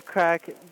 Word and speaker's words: craic: So is crack, it's spelled C-R-A-I-C. Is that a proper spelling, or did you just tweak it a bit so it looks like crack? – No, that's the Gaelic spelling of it.